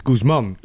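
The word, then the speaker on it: Guzmán: Ik ben nog steeds een beetje aan het bekomen dat je Guzman moet zeggen in plaats van Guzman. – Het is Guzman. Okay. Ja, de klemtoon ligt op de man.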